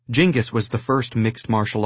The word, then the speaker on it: Genghis: Greg makes a point to pronounce the name correctly, with a soft G, Genghis.